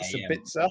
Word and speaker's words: Sabitzer: Is that it? Is that how you pronounce it? Sabitzer? Yeah, and then I thought, and you got Weghorst on loan.